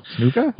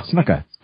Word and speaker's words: Snuka: Snuka? – With, against the team Snuka. – Snuka. – It's pronounced either way, yeah. I mean, it's changed throughout his career.